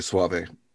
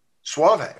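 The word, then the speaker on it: Soave: Is that how you pronounce his last name? – I understand it's Robbie Sauve. – Sauve, okay. Boy, I wasn't even close.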